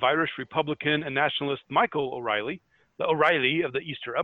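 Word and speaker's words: O'Rahilly: Pronounced more like O'Reilly.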